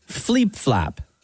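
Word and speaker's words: Fliep Flap: It's spelled F L I E P. Flap, F L A P. Fleep Flap.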